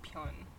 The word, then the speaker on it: Pyun: But I have a cousin that went to BCHS, and they pronounced it Bion because it's B-Y-O-N. So Bion, when our last name is Pyun.